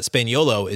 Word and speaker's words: Spagnuolo: And then really interesting thoughts from Rafael Barlow on Matteo Spagnuolo, And I pronounced his last name wrong last week, so I apologize for that. Spaniolo is how you say his last name.